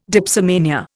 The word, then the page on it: dipsomania: Indian